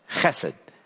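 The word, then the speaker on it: chesed (חסד): The word is chesed. And it's, we don't usually pronounce sounds, but chesed.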